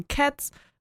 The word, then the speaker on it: Keds: Sie war nämlich auch schon in verschiedenen Werbespots, unter anderem für die Schuhmarke Cats, also nicht Cats wie Katzen, sondern KEDS, wird trotzdem so ausgesprochen, Coca-Cola.